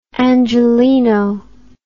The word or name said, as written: Angelino